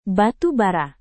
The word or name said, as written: batu bara